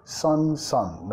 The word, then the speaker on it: sun: Pronunciada igual a hijo, ¿no? Sol y hijo, pronunciado igual. Sun, sun.